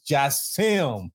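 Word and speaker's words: Jassiem: This is your boy, you know who, the J, the A-S-S-I-E-M. And if you don't know how to pronounce it because you hooked on phonics, it is Jassim.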